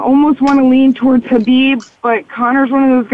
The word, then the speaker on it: Khabib: Habib. The K is silent.